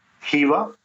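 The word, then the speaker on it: Khiva: The highlights of Uzbekistan are the cities of Hiva. It's spelled with a K, but the K is silent, so it's H-I-V-A is how you pronounce it.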